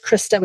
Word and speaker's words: Christa: so my name is Christa with a CH,